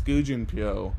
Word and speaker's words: Gu Junpyo: Because Jandi and Gujun are done. – You keep saying his name wrong. – No. – It's either Mr. Gu. – Oh, I'm sorry. – Or it's Junpyo. Or it's Gu Junpyo.